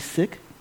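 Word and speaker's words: Sikh: One thing I did learn from Parliament was that I learned how to say seek wrong. It's actually sick.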